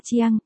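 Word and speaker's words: Chiang (蔣): B, Tưởng trong tiếng Trung đọc là Chiang, gần giống với cách phát âm của từ Khương Chiang.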